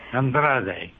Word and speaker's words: Andrade: Andrade.